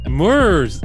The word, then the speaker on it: Murs: You know Moors, Mures, MURS. – Mers? – Mers. Oh, Mers.